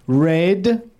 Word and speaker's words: Rade: Rade, R-A-D-E, or Rod.